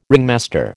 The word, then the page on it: ringmaster: American English